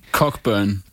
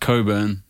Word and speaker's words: Cockburn: Cockburn. Which is pronounced Coburn.